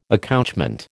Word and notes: accouchement: American English

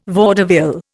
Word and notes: vaudeville: Indian

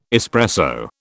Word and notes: espresso: British English

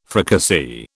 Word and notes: fricassee: British English

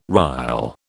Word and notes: rile: British English

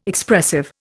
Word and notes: Expressive: Indian

